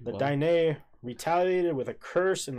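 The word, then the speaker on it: Diné: The plentiful fresh water and prime hunting grounds were paradise to the Dine, pronounced Dine, as they called themselves.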